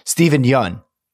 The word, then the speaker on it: Steven Yeun: Steven Yeun. That's how it's pronounced.